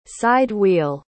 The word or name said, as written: side-wheel